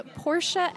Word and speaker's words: Portia: So it is Portia L. Art, and my name is spelled P-O-R-T-I-A.